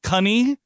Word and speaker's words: cunny: I expected money to get rhymed with cunny, which is a ye olde synonym for charisma, uniqueness, nerve, and talent. – That's kind of fun to say, though, cunny. – Yes, and rhymes with money.